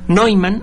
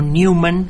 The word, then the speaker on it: Neumann: Neumann, o Newman, si lo pronuncia usted en inglés en lugar de en el europeo original.